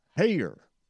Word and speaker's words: Haier: Hair. And I'm not talking about what's not on his head. It's spelled H-A-I-E-R, hair.